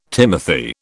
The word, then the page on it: timothy: British English